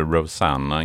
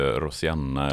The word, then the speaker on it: Rosanna: Idag ska vi prata Rosanna. – Mm. – mm. Du uttalade Rosanna, inte Rosianna eller på något annat sätt.